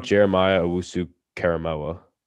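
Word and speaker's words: Jeremiah Owusu-Koramoah: I'm going to go Jeremiah Owusu Karamoa. Take him off the board. Wow, you pronounced that perfectly. – I have no idea. – Yeah, no, you did.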